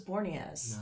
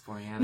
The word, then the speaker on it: Boreanaz: I always thought it was Boreanaz. – No, it's Boreanaz.